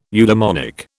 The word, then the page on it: eudemonic: British English